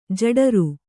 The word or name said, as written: ಜಠರೆ